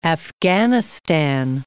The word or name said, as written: Afghanistan